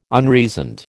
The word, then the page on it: unreasoned: American English